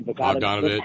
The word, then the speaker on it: Bogdanovich: And then Hugh Hefner and Peter Bogdanovich, whatever his name. – Bogdanovich. – I can never pronounce that. Thank you.